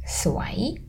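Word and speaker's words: Suai (สวย): Very good. Suai with rising tone. The next adjective, let's say bad luck. – Suai. – Very good. So suai, rising, is beautiful. Suai, mid tone, means bad luck.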